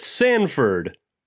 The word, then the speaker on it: Sanford: Moving to New York to pursue her dreams of becoming a famous songwriter, Violet Sanford, rhymes with Hanford, Piper Perabo, finds herself desperate and broke.